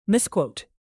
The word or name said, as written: misquote